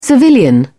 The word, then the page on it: civilian: British English